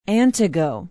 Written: antigo

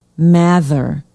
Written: mather

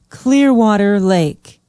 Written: Clearwater Lake

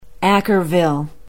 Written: ackerville

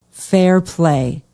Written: Fairplay

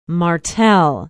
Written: martell

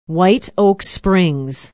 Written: white oak springs

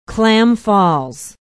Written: Clam Falls

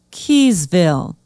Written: keyesville

